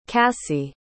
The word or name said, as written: cassi